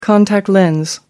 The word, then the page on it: contact lens: American English